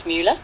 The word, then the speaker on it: Mueller: as long as you have the spelling of my last name right, which is M-U-E, not M-U. A lot of people spell it around the wrong way. So it's Mueller.